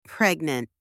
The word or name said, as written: pregnant